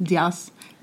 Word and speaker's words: Diaz: Diaz is Cuban. – Great pronunciation.